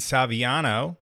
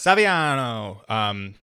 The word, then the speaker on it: Saviano: I'm very excited to be joined at this point with Brian Saviano. I'm saying that right? Brian, yes, Saviano? Absolutely correctly. It's a little Italian. Saviano, yes. – Saviano.